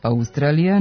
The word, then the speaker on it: Australija: Oblik Australija naveden je u matičinom rečniku sa kratko uzlaznim akcentom na drugom slogu i dužinom na sljedećem, tamo gdje se u razgovornom jeziku obično čuje dugosilazni akcenat, što je van akcenatske norme, pošto silazni akcenti mogu da stoje samo na prvom slogu u reči.